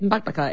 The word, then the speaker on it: Bakbaka: There's another recipe, and boy, I'm not confident that I will pronounce this correctly, but it's the one pan Bakbaka. Yeah. Yeah. That's it. You did well. That's it. Bakbaka. It's an unusual word.